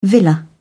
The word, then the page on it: villa: British English